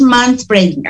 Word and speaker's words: manspreading: Entonces, yo creo que eh, no es mens, es manspreading, así se pronuncia. No, es que se escribe men, pero se pronuncia man, para que la busquen en internet, porque mucha gente de verdad no sabe, em, no sabía de este término.